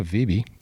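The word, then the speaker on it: Wiebe: So Gunnar Vibe, current OWGR is 2,872. And according to his LinkedIn profile, is the assistant professional at Bel Air Country Club. I'm assuming it's the same Gunnar Vibe, but there you go.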